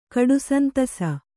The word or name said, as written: ಕಡುಸಂತಸ